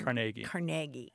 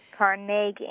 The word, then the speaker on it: Carnegie: Carnegie. – Carnegie, accent on the second syllable. – With more of an A than an E.